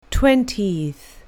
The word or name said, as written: twentieth